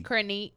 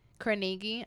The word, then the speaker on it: Carnegie: She's the first person of color to get the Carnishaw. – Girl, look. – I still can't pronounce that word. – Carnegie, baby. – Carnegie. – Carnegie. – Carnegie, girl.